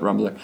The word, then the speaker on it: Rumbler: Spelled like Tumblr, but Rumbler. – With the R. – Yeah, Rumbler, with the R. No E.